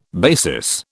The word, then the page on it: basis: British English